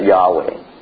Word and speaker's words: Yahweh: It's Yichwich, right? But we pronounce it Yahweh.